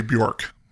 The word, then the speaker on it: Björk: Her name is Björk. – Okay. – That's actually how you say it. I always say Björk.